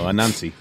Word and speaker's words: Anansi: I like that you're really pronunciating, because I almost thought you said Nazi Boys for a minute. – No, Anansi.